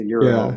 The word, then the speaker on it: gyro: I know the right way to say it is a euro.